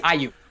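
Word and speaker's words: Aiyuk: Is it Brandon Ayuk? Did I say that right? – Ayuk. – Ayuk, okay, Brandon Ayuk.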